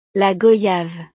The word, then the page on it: la goyave: French